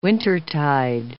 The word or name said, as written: wintertide